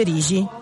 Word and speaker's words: Rixi: Edoardo Rigi. – La X si legge G in genovese come in portoghese. – Si scrive Rixi, si legge Rigi.